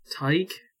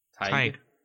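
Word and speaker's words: Teig: So the way this, like, website, like, says it is, it's T-E-I-G is how you, like, pronounce it. Like, is... What's that word? Tig? – Tig. Huh. That is actually how, what dough is in German, so...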